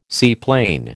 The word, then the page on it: seaplane: American English